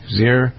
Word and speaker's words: Zyrtec: Doug Steffen with a focus on a question about Zyrtec. Z-Y-R. Is that how you pronounce it? Zyrtec? Zyrtec? – Zyrtec, yeah.